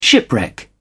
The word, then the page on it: shipwreck: British English